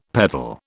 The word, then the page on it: peddle: British English